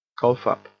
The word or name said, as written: cough up